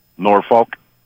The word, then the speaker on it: Norfolk: Norfolk.